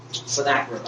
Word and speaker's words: Sennacherib: Sennacherib, I believe is the correct pronunciation.